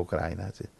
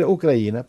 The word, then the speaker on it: Ucraina: Allora sono andato a Gluglu, dove dice traduzioni, c'è anche l'audio, e dice Ucraina. Quindi adesso devo cambiare l'accento, perché a volte dico Ucraina. Dobbiamo dire Ucraina, perché così dicono in Ucraina.